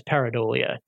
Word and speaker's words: pareidolia: You use the analogy to, I'm not sure I'm going to pronounce this correctly, pareidolia? – Pareidolia, yes.